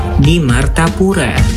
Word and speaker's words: Martapura: Di Martapura. di Martapura, which means in Martapura. I will pronounce it slowly. You can repeat it after me.